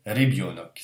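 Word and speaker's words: Rebionok (ребёнок): Et le russe accentue toujours le yo, en fait. Ce qui fait qu'on va atténuer encore un petit peu cette lettre-là. Et ça se prononce en fait Rebionok. C'est presque un i.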